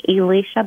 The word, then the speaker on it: Elysia: If you just type in the title, Become the Hero of Your Own Story, you can also just type in my name, Elisha Butler, and my first name is spelled E-L-Y-S-I-A, and then Butler is B-U-T-L-E-R.